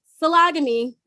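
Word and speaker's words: sologamy: But anyway, so again, to clarify, to summarize, phylogamy, which is how we're going to go ahead and pronounce it, according to Wikipedia. – It's also just like salami.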